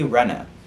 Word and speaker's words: Renna: I spelled it R-E-E-N-A, it's spelled R-E-N-N-A. Kathy Renna is the activist I was referring to. There's her picture again, because she's gorge. And yeah, it's Renna, not Rena, I'm sorry.